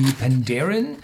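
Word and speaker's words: Penderyn: Und die Pandarin, das ist ein gälischer Name, äh, wird also damit auf der zweiten Silbe betont.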